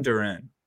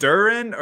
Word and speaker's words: Duren: Wait, also, I need to get, is it Duren or Duran? – I think it's Duran, but... – Jalen Duren. Half commentators are watching pronounce it differently.